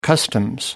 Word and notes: customs: American English

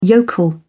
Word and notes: yokel: British English

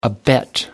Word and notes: abet: American English

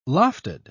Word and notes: lofted: British English

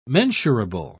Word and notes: mensurable: British English

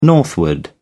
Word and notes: northward: British English

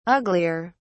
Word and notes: uglier: British English

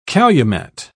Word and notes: calumet: British English

